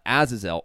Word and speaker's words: Azazel: Azazel. I don't know if I'm saying that right. Do you know, is that the right way to pronounce that? – It sounds right to me. – Azazel.